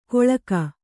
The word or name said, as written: ಕೊಳ್ಕ